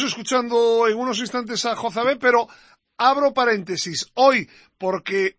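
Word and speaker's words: Jozabed: El propio interesado hoy me ha dicho personalmente que es un nombre bíblico y que la pronunciación correcta y exacta es Jozabé.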